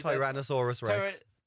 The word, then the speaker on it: Tyrannosaurus Rex: T-Rex, Erectinosaurus. I can't pronounce it, man. I can't pronounce it, honestly. – Tyrannosaurus Rex.